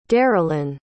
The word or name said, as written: darylyn